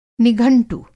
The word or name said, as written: निघंटु